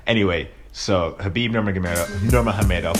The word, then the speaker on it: Nurmagomedov: Obviously, it's been a few days since Khabib Nurmagomedov, or Nurmagomedov, that's how you actually pronounce his name, you don't pronounce the G, but I'm just a stickler for that type of stuff.